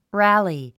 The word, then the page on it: rally: rǽli; ラァリィ